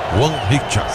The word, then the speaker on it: Hwang Hee-chan: Hwang Hee-chan.